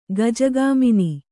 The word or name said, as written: ಗಜಗಮನ